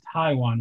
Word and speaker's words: Taijuan: I think having Carrasco on his team, you're able to bump a guy like Taijuan Walker because you guys are butchering his name. So it's Taijuan Walker.